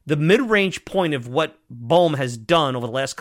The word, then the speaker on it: Bohm: Because when you look at an Alec Bohm, and I always say his name wrong, let me make sure. Bohm.